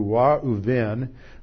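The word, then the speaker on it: Ra'uven (רְאוּבֵן): So Reuben, and I have the transliteration up here from the Hebrew Ra'uven, which is how it would be pronounced, if not the anglicized form Reuben, sounds like the phrase Ra'avyoni.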